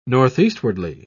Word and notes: northeastwardly: British English

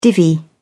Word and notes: divvy: British English

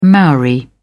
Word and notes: maori: British English

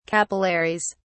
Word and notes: capillaries: British English

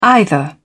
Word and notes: either: British English

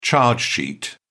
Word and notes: charge sheet: British English

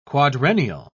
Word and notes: quadrennial: British English

